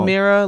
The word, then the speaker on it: Mira: No, Mira, like mirror on the wall.